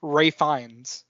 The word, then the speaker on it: Ralph: Ray Fiennes is how mm-hmm. you say his name, not Ralph Fiennes.